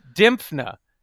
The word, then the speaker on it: Dymphna: Dimphna. – How do you spell it? – D-Y-M-P-H-N-A. – Huh. It's a lovely name, Dimphna.